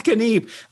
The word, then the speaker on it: Kniep: Kniep in it real. – Yes, right, right. Seth Kniep, co-founder and CEO.